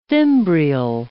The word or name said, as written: fimbrial